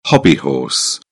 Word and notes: hobbyhorse: British English

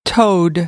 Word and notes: toad: American English; oʊ

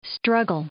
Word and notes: struggle: verb

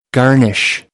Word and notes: garnish: American English; ɑːr - ɑː

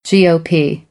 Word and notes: GOP: American English

